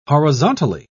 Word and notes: horizontally: British English

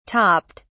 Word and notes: topped: British English

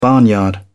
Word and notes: barnyard: British English

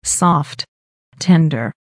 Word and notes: soft tender: American English

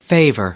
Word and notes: favor: American English

